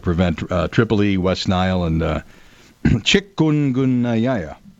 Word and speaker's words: chikungunya: You know, I actually learned how to pronounce that this week. – Fire away. – Chikungunya. – Chik. – So you did it pretty damn well.